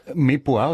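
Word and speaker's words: mirepoix: Mir Poir oder wie? – Mir Poir. – Mir Poir. Mhm. Was ist das? – Feinwürfelig. – Mir, wie schreibt man das? – Das ist Französisch.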